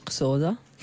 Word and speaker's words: Xhosa: Sì, che è strano, questo in lingua Xhosa. Si pronuncia con un click, una cosa, non è un errore tecnico. – Non è un errore tecnico, si pronuncia con un click all'inizio.